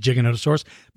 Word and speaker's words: Giganotosaurus: Or Giganota, depending on how you pronounce Giganotosaurus or Giganotosaurus. I pronounce it Giganotosaurus.